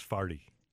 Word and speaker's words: forty: Now, that's something that St. Louisans have to trademark is farty. – Is it spelled as exactly as it sounds? – No, F-A-R-T-Y? – It's spelled F-O-R-T-Y.